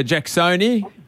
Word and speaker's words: Jackson: Jackson. The silent N? – Yeah. – You don't even say the N. – Jackson. – Yeah. – It's in the back of your throat.